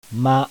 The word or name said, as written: MA